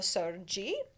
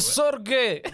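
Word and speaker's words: Sergei: Essentially what happened is that I'm pretty sure Strahd had a brother, Sergi, or Sergey, however you want to say it. I don't know. – It's Sergey.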